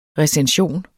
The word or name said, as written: recension